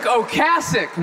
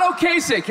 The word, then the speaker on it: Ocasek: Rick Ocasek, not Ocasek, you're all saying it wrong.